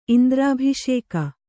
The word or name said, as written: ಇಂದ್ರಾಭಿಷೇಕ